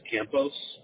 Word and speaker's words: Campos: E-R-I-C. First name. My last name is Campos. That's spelled C-A-M-P-O-S.